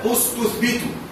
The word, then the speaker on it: استثبت: كلمة استثبت، إن بدأت قلت أستثبت، أستثبت بنطقها، وإن وصلت كما فعل كس تثبت بدون النطق، واضح هذا الكلام؟